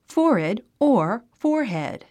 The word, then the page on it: forehead: fɔ́ː r hèd; フォアヘッド